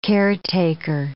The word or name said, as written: caretaker